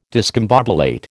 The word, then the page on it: discombobulate: American English